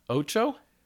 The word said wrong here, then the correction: Ochi: Ochi. – Ochi. – Yeah. – Why did I get his name wrong? Crap. Ochi, right?